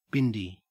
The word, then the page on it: bindi: Australian English